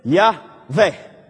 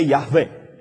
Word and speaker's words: Yahweh: Now, this is Yahweh. Can you pronounce it? Yahweh. Now, today, English, for some reason, English people don't like, they don't know how to pronounce it or whatever. For years, they call it Jehovah. Jehovah. But I was thinking, why say Jehovah when you can say Yahweh?